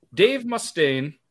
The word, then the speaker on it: Mustaine: Before forming Megadeth, Dave Mustaine, sorry, that's how you say it, right? Yep.